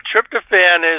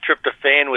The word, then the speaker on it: tryptophan: Well, tryptophan is the American version. I think tryptophan with an E at the end is more the British version.